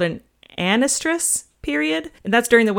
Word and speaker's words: anestrus: So it's like estrus, but with a n in front of it. An estrus. An estrus.